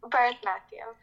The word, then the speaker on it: Bharatanatyam: Did I say that right? – Bharatanatyam, yeah.